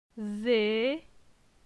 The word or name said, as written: Ze